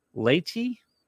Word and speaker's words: laity: The practice was later institutionalized by Pope Urban II, who in 1091 decreed that on Ash Wednesday, everyone, clergy, laity, you familiar with that word, L-A-I-T-Y? – It's pronounced somewhere, something else. – Clerk and laity? – Laity. There you go.